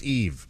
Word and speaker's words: Yves: That's pronounced Eve.